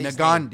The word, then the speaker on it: Ngannou: How do you say Ngannou.